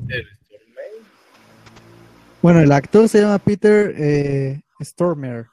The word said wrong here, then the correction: Stormare: no sé cómo se pronuncia eso. Pero, Stormare.